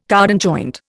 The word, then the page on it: cardan joint: Indian